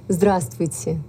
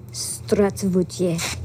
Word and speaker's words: Здравствуйте: Здравствуйте. – Здравствуйте.